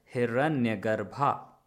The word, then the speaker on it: Hiranyagarbha: I think it's a Sanskrit word which is pronounced rather as Hiranyagarbha.